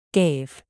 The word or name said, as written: gave